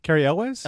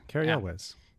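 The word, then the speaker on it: Cary Elwes: Carrie Elwes? – Elwes, is that how you say it? – Yeah, Carrie Elwes.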